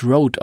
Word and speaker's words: Rode: Das tatsächlich wird das Road ausgesprochen. – Das macht, das ist ein Eigenname. Das macht nichts. Aber da das O so durchgestrichen ist, habe ich es auch mal als Röde verstanden.